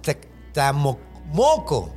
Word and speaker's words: Tecamoco: Te convertía en una madre que se llama Tacamoco. es que no sé cómo se dice. Se, escribe T-C-A, Tacamoco. Ah, ok. Tacamoco. – Tecamoco. – No, es Tecamoco.